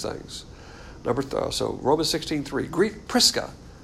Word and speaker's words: Prisca: So this is the Roman pronunciation of her name, Prisca, and Aquila.